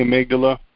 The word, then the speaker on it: amygdala: almagada for our memory piece that we need to spend more time on. – Yeah. Did you mean the amygdala? – Amygdala.